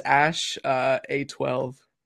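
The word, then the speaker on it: X Æ A-12: No, his name, I believe it is pronounced as Ash A12. Because I think, yeah, it's like A-E-X. That's supposed to mean Ash.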